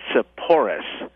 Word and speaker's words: Sepphoris: This is the famous site of the ancient city of Sepphoris. Some people pronounce it Sepphoris.